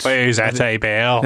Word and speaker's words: vous êtes belle: which is "vous êtes belle." Forgive my desperate pronunciation, but I did. – Vous êtes belle.